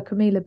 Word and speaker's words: Camilla: And I love that she didn't, she was like, no, in England we pronounce it Camilla, and like she's kept with that.